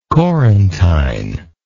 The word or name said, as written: Courantyne